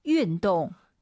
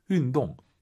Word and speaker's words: 运动: YU 발음은 입술을 동그랗게 말아서 윈, 윈동, 윈동 이거예요.